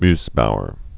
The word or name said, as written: Mossbauer effect